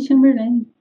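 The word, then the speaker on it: Chamberlin: Como, é, como que pronuncia o sobrenome dele? É Chamberlain? – É, Jimmy Chamberlain. – Chamberlain faz, né?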